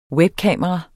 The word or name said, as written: webkamera